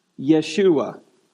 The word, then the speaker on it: Yeshua: Now, how do you say Jesus' name in Hebrew? Yeshua.